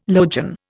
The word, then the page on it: logion: Indian